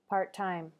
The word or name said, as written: part-time